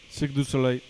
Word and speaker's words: Cirque du Soleil: Cirque du Soleil.